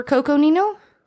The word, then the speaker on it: Coconino: You butchered that, I'm sure, but... – C-O-C-O-N-I-N-O. Coconino. – Yeah. – I didn't butcher it.